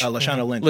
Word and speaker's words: Lashana Lynch: And I think for me, one of the biggest standouts was what's her name? Latasha Lynch? – Lashana Lynch. – Lashana Lynch as it's been butchered.